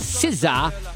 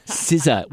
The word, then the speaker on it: SZA: But you had nominations in here from Bad Bunny, Sabrina Carpenter, Doechii, Billie Eilish, Lady Gaga, Kendrick Lamar with SZA, Chappell Roan, and Bruno Mars.